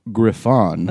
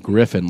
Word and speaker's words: Griffon: He is 50% Brussels Griffon. – That's how you say it. – Not Griffin, like some fucking piece of white trash. – People in the Griff community, by the way, yeah. – you have to say Griffon.